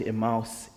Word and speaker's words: Emmaus: Further on, if you read 13 to 35, on the road to Emmaus, we call it Emmaus, is the correct pronunciation.